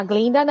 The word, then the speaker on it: Glinda: Porque a Glenda fala, né? Ah, eu posso, né, consertar esse problema. E não é um problema. – Não, a Glinda não. A Glinda. Você tá pronunciando errado.